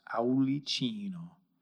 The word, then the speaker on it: Aulicino: That is spelled Aulicino, pronounced Aulicino.